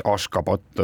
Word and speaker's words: Ashgabat: E, ja e, selle pealinna e, tänapäevane õige kirjapilt ja hääldus on Ashgabat ja,